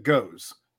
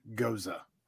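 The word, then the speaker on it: Gose: And I pronounce it Goes, as is phonetically correct. I am not pronouncing that word Goza.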